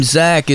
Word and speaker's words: Zach: I think I do it because my name Zach is spelled with a CH, and it's with a, you know, it sounds like a K.